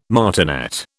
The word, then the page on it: martinet: British English